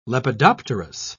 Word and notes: lepidopterous: British English

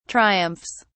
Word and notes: triumphs: British English

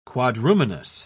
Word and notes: quadrumanous: British English

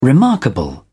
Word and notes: remarkable: British English

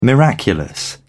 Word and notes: miraculous: British English

